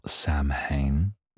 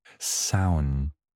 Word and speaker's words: Samhain: So, speaking of pronunciations, Halloween, or not Samhain, as I was corrected by multiple people. Samhain. I think I've got that right this time.